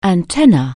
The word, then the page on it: antenatal: anglais